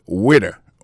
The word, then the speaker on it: waiter: Alors moi, je prononce le waiter. Waiter, ça veut dire accent tombé sous le premier syllabe là. Waiter. W-A-I-T-E-R.